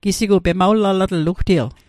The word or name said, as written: pemawlalatl